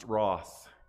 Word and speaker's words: wrath: I didn't have the accent, but I did try to pronounce words the way they pronounce them, and wrath is pronounced wroth.